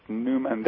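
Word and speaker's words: Neumann: In fact, the best way to contact me directly is just my name, Robert Newman. That's spelled N-E-U-M-A as in apple, and double N at the end, so two N's, mm-hmm.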